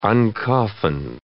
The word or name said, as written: uncoffin